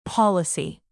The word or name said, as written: policy